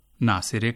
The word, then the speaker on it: ناصره: سامعین آپ نے یہاں جو جملے سنے ان میں خواتین کے جو نام ہیں رضیہ، ناصرہ اور نسیمہ، ان کا فارسی تلفظ آپ نے ملاحظہ کیا کہ رضیہ کو رضیہ کہا گیا، ناصرہ کو ناصرے کہا گیا اور نسیمہ کو نسیمے.